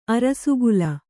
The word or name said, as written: ಅರಸುಗುಲ